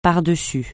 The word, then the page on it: pardessus: francais